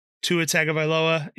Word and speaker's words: Tua Tagovailoa: Same case with Tua Tagovailoa in Miami.